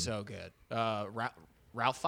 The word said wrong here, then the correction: Ralph: Fiennes, right? – Ray. – Ray Fiennes? – Yeah, it's spelled like Ralph. – Yeah, yeah. Ray Fiennes. – Actually, I think it's pronounced Rafe.